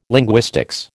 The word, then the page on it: linguistics: American English